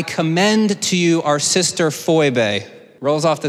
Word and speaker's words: Phoebe: Uh, fun anecdote in the Greek, the way that you pronounced Phoebe is actually Phoebe.